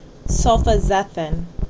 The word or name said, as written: Sulfazethin